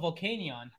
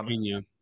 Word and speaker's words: Volcanion: Oh, oh, you, oh you're talking about Volcanion. – Volcanion. Yeah, Volcanion.